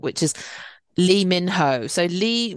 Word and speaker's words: Lee Min Ho: So his actual name is Lee Min Ho. So it's really tough because the mostly the Korean surname that is anglicized as Lee is E, E.